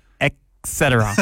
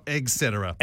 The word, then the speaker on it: et cetera: How do you say it? – Et cetera. – It's hard to change, isn't it? – It is. – Et cetera. – Et cetera. – Not egg cetera.